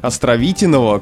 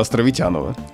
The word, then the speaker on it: Островитянова: Человек, в честь которого улица получила название, Константин Васильевич Островитянов с ударением на «и», отечественный экономист и общественный деятель. И улица должна была бы стать, конечно, улицей Островитянова. Но мы опять упираемся в удобство произношения, потому что название широко используется, в первую очередь, конечно, местными жителями.